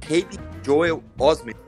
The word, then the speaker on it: Haley Joel Osment: Eu nunca sei falar o nome desse ator. Deixa eu só dar uma colada aqui. Que é o Haley Joel Osment. É isso? É assim que se pronuncia? Isso, isso. Isso.